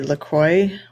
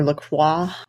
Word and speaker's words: LaCroix: I really enjoy, and both Julie and I do, we enjoy LaCroix or LaCroix, if you're going to say it like the French way.